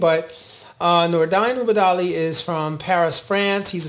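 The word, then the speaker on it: Nordine Oubaali: And now, so let's move on to Nonito Donaire and Nordain Ubadali, right? That's how you say it? – Good one. You got it.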